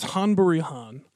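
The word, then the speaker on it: Ghân-buri-Ghân: Han Buri Han, which is spelled with a G, apparently. – G-H-A-N. Buri, G-H-A-N. – Yeah, it's Han Buri Han.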